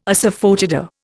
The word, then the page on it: asafoetida: Indian